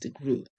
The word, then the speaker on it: DeGruy: Her name is spelled D-E and then G-R-U-Y. Joy Leary DeGruy. It's pronounced DeGruy.